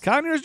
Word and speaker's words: Conyers: No, it rhymes with Fonyers. – What? Conyers? – Conyers, Georgia, yeah.